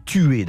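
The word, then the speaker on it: tuyé: Pour essayer d'être un petit peu descriptif, on va dire que le tuyer, ça se prononce tuer d'ailleurs, on prononce pas trop le ye apparemment,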